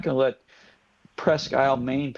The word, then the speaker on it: Presque Isle: First, Steve, did I say that correctly, Presque Isle? – Yes, but it's Maine.